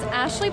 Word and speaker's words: Ashley: My name is Ashley Boreff, and that's A-S-H-L-E-Y. Last name, B as in boy, O-R-U, F as in Frank, F as in Frank.